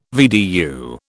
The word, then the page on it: VDU: British English